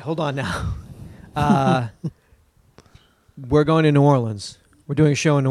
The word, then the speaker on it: New Orleans: No, New Orleans.